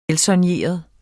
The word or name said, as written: velsoigneret